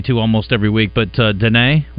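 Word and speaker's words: Diné: So and it's D-I-N-E, it's like this little accent mark, you know, over the E, and it's pronounced Dene.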